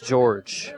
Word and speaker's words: Jorge: It's spelled like Jorge, J-O-R-G-E, but according to the Sussex County roster, it is pronounced George.